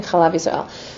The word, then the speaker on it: Chalav Yisrael: Chalav Yisrael, as they pronounce it in this country, Chalav Yisrael.